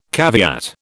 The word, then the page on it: caveat: British English